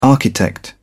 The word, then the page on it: architect: British English